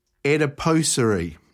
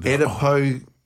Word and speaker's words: adipocere: My word is adipocere.